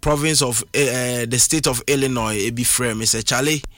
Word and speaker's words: Illinois: the word is Illinois Sni is silent and Illinois no the biggest city Ewa Illinois and one of popular Ghana hapa etabu ni dise Chicago no.